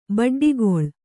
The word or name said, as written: ಬಡ್ಡಿಗೊಳ್